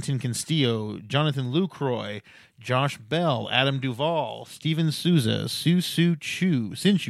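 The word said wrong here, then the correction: Shin-Soo Choo: Shin-Soo Chu, I say it right because he won me a title,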